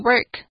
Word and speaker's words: work: 接着呢，我们来看看 work 这个字， or 呢是发作 er 的音， work 很多人可能会发成 or 的音，大家要注意一下哦，是 work。